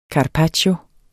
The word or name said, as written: carpaccio